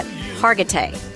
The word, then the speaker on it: Hargitay: Hargitay.